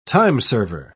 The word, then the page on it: timeserver: British English